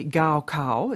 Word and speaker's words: Gaokao: Well, interestingly, you moved earlier this year to change enrolment conditions for those who've sat the Gaokao, is that the correct pronunciation? – That's right.